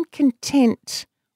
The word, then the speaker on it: content: You know, if you put the emphasis on the second syllable, I am content mostly with my creative choices.